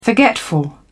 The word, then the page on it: forgetful: British English